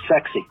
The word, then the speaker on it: CEXC: So the initials were CEXC, pronounced sexy.